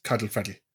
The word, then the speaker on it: Colin Farrell: It's spelled Colin Farrell, but phonetically it actually works out as Cuddle Freddy.